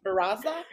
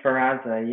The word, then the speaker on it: Sferrazza: I don't know how to say your last name. – Sferrazza? – It's Sam Sferrazza, yeah. Sferrazza.